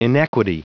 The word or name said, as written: inequity